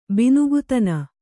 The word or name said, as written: ಬಿನುಗುತನ